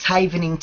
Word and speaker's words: Havening: Am I saying that correctly? – Yes, that's correct. It's Havening Technique.